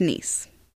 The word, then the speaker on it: Nice: Is it pronounced Nice? – It is pronounced Nice. – It's kind of like Harcourt Street.